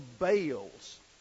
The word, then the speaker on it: Baal: the religion of the Baals. B-A-A-L-S. Now, in Hebrew, that term is pronounced Baal. In North Carolina, we pronounced it Baal. You know, in North Carolina, you can make almost any word two syllables.